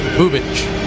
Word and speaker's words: Bubic: Spelled B-U-B-I-C. Pronounced Chris Bubich.